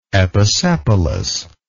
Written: episepalous

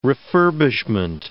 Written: refurbishment

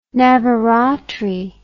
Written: navaratri

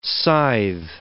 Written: scythe

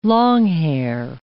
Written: longhair

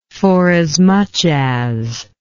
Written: forasmuch as

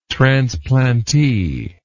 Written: transplantee

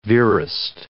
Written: verist

